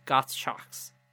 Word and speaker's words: Gottschalks: It's called Gottschalks. Gottschalks. – I'm sorry. How do you spell it? – G O T T S chalk. C H A L K S. Gots chalks.